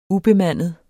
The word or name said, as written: ubemandet